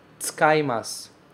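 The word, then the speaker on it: tsukaimasu (使います): Esse u, ele quase não é pronunciado. A gente não fala normalmente tsukaimasu, tsukaimasu. A gente fala tsukaimasu, tsuka, tsuka, tsuka. É quase como se não existisse esse u.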